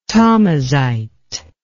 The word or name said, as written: Tamazight